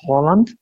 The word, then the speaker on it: Haaland: Er ist Norweger und weiß deswegen, wie er eigentlich ausgesprochen wird, nämlich Holland. Ja, das ist dieses äh, norwegische Ö irgendwie und das so ähm, ausgesprochen wird. Haaland selbst hat ähm, in einem Video gesagt, dass er lieber Haaland ausgesprochen wird.